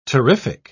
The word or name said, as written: terrific